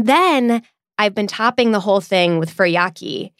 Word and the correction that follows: furikake: Is that how you say it? – I say furikake, but I've never heard anybody with any authority on the topic pronounce it.